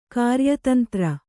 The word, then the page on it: ಕಾರ್ಯತಂತ್ರಿ: kāryatantri